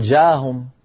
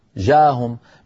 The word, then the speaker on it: جاءهم: يعني مثلا يا بني لا تقل جاهم جاءهم، بين الألف والجيم هناك همزة، جاءهم أهم، هذه الهمزة لابد من نطقها.